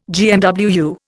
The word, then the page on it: GMWU: Indian